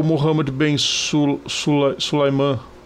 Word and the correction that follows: Sulayem: Sulaiman ou Sulaiman? Eu nunca lembro a pronúncia do nome dele. – Vamos ver aqui. – É dos Emirados Árabes, que é apoiador ultraferrenho da Arábia Saudita. – Mohammed Bin Sulayem. – Sulayem, isso. A FIA resolveu, e a Fórmula 1 também, resolveram seguir com a corrida.